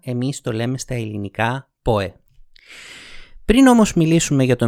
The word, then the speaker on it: Poe: Ξέρω πάρα πολύ καλά ότι η αγγλική προφορά του ονόματος είναι «πόου». Αλλά στα ελληνικά τον λέμε «πόε».